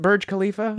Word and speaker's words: Burj Khalifa: Is that how you pronounce it? Burj Khalifa? – Yes.